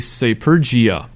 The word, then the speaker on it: Sapergia: So if I'm writing in a document S-A-P-E-R-G-I-A, when it sees that, it would pronounce it like S-A-P-E-R-J-A. And usually it gets it right.